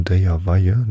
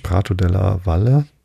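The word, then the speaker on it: Prato della Valle: Der große Platz, Prato de la Valle, hätte ich beinahe spanisch ausgesprochen, de la Valle, nee, es ist italienisch, Prato de la Valle, hoffe ich, genannt ist ein sehr weiter Raum, wo der Hauptmarkt im Juni gehalten wird.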